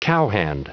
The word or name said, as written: cowhand